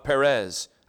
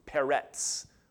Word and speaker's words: Peretz: Now these are the generations of Perez. Actually, it would have been pronounced Peretz with a T-S, Peretz.